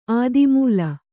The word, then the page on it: ಆದಿಮೂಲ: ādimūla